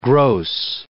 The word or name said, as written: gross